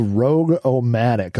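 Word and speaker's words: Rog-O-Matic: Now, one thing that I found really interesting around this same time was a program that was released called Rogue-O-Matic, and that is spelled R-O-G-O-Matic, but it's pronounced Rogue.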